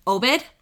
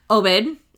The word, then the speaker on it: Obed: I think it's Obed. I think it's how they pronounce it, O-B-E-D.